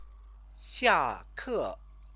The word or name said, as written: xiake